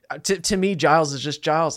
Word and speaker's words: Giles: Where, like, because we know, obviously, that it's a soft G, so it's Giles.